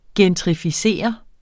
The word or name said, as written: gentrificeret